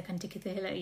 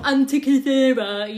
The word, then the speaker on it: Antikythera: I only like the way that Mads Mikkelsen pronounces the Antikythera, you know, he sounds just like, accent. It sounds like that's the way it's meant to be said, not Antikythera, you know.